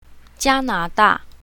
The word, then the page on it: jia1na2da4: mandarin